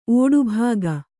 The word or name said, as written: ಓಡುಭಾಗ